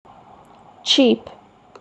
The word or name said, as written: cheap